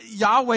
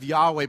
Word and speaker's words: Yahweh: Actually, we don't really know how to say it because it's four consonant sounds in the Hebrew. They don't have vowel sounds. We got to kind of provide them for those words. So Yahweh is kind of the way that we've said it.